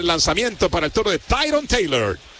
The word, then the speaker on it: Tyrone: ¿Cómo se pronuncia, Eduardo? Bueno, por favor. – No es Miller, no. – No, pero es okay. Tyrone, ¿no? – Oh, Tyrone, sí, te felicito. – En San Félix decimos Tyrone.